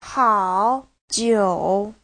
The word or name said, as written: hao3jiu3